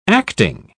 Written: acting

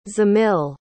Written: zemil

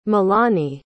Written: malani

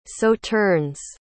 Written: sauternes

